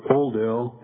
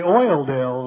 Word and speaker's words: Oildale: Yeah, you have one of those neighborhoods too, and that's where we landed in Bakersfield, and I soon upgraded later on to Oldale. And now, some of you don't say it right. You say Oildale, and we just look at you like, where's that?